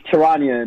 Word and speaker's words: Charania: It's Charania, and you don't butcher the man name.